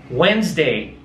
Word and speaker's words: Wednesday: Perceba que você não vai pronunciar esse D de Wednesday, é Wednesday, Wednesday.